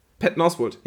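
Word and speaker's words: Patton Oswalt: Ich weiß gar nicht, wie spricht man aus? Peyton Oswalt? – Peyton Oswalt, ja.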